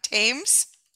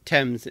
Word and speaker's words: Thames: What is the name of the world-famous gardens situated 10 miles outside of London, close to the river Thames? – Thames is how you say it. – Thames. Thank you.